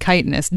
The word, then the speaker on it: chitinous: It's pronounced chitinous, doctor.